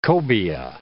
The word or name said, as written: cobia